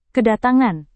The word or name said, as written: kedatangan